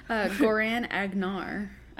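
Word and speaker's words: Goran'Agar: how does he say his name? Gornabjar? – Goran Agnar. Agnar.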